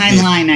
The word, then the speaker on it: Heinlein: I'm never sure if I pronounce this correctly, Heinlein Award. – Correct. Heinlein, I believe, but yes.